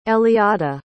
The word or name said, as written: eliada